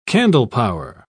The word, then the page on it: candlepower: British English